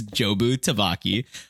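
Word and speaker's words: Jobu Tupaki: You understand the villain's perspective being Jobu Tabaki. That's a name. I'm not just saying gibberish. It's Jobu Tabaki, or as Michelle Yeoh's character says a lot, just mispronounce it as Chewbacca Dubaca, Jerky Chubuki.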